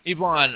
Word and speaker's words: Yvonne: Yvonne, all right.